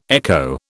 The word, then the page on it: echo: British English